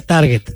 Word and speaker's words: target: Se dice target, target. Sí. A ver, aquí está el target. No, es target. En inglés se pronuncia target.